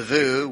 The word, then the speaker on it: voo: The voo, which rhymes with you, and it means you to the church because they care about people, all people. This term is usually pronounced voo as in voodoo or vo as in dough.